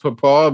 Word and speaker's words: pawpaw: Yeah, but Michael, in Barbados, it's pronounced papaw, but it's the same thing as papaya here in the United States.